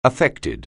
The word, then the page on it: affected: anglais